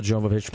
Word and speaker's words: Jovovich: Milla Jovovich, Jovovich went all the way through it, and I pronounced it correctly.